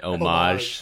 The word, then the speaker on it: homage: say homage? – He did. – You mean homage? – Homage, whatever. – Like a fromage.